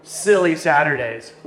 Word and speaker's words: Sili-Saturdays: Sili-Saturdays. What's up with Sili-Saturdays? – The silly in that term is spelled S-I-L-I, like silicone. So